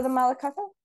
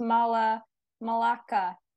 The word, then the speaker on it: Malacca: It's pronounced Malacca. Strait of Malacca.